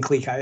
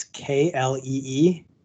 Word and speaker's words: Klee-Kai: Klee-Kai? – Yeah, it's an Alaskan Klee-Kai. It's Klee-Kai. Klee-Kai.